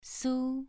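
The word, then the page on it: su: tone 2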